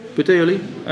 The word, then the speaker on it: Puteoli: So they come to, can you please pronounce this, Puteoli? Puteoli? Puteoli. Okay, and we meet the believers.